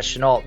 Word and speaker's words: Shenault: Yeah, Shenault.